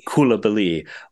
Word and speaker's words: Coulibaly: And you're supposed to put a little French on the end of Coulibaly. – Coulibaly.